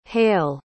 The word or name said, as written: hayle